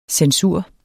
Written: CENSURS

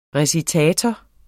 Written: recitator

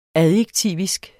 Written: adjektivisk

